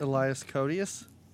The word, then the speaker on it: Elias Koteas: Elias Koteas.